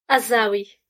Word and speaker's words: Azzaoui: Azawi.